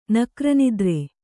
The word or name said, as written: ನಕ್ರನಿದ್ರೆ